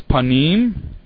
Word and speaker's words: panim: The next word that we're going to look at here is panim, and it's pronounced pane in the text.